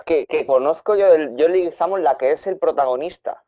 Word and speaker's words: Leguizamo: Woody Harrelson, Andy García, Matt Damon o John Leguizano. Leguizamo, no sé si pronuncio bien ese. Ah, no, es, es John Leguizamo, correcto.